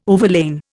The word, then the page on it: overlain: Indian